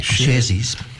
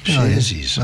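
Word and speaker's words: Sharesies: I'm glad you could pronounce that. It's a funny spelling. – Sharesy's. – Sharesy's. – Oh, yeah. I gather that's how you pronounce it.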